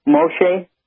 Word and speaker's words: Moshe: As I understand it, the law was given to Moshe. Is that the right way to say Moses? – Well, that's the right way to say his name.